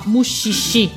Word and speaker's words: Mushishi: Cominciamo per esempio da Mushishi, tu mi dici vero si, si, produ- si pronuncia così, che è una serie molto particolare.